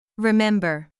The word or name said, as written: remember